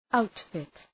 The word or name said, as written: outfit